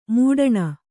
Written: ಮೊಟ್ಟನೆ

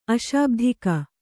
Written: ಅಶಾಬ್ಧಿಕ

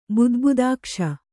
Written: ಬುದ್ಬುದಾಕ್ಷ

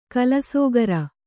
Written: ಕಲ್ಲುಸಕ್ಕರೆ

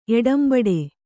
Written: ಎಡಂಬಡೆ

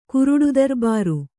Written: ಕುರುಡುದರ್ಬಾರು

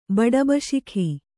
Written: ಬಡಬಶಿಖಿ

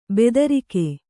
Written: ಭದ್ರಕ

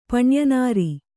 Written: ಪಣ್ಯನಾರಿ

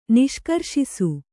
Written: ನಿಷ್ಕರ್ಷಿಸು

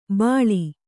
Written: ಬಳಿ

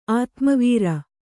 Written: ಆತ್ಮವೀರ

